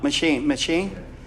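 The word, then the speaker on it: M'Cheyne: I can't pronounce this. McChain. What is it? M. Machine. Yeah, this is a Scottish name.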